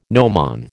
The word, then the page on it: gnomon: American English